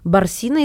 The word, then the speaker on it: Барсина: Барсина, если я верно ставлю ударение, стихотворение, дочь Верно.